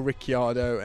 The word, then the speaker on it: Ricciardo: Shades of Daniel Ricciardo and F1, which drives me mad because it definitely shouldn't be pronounced Daniel Ricciardo.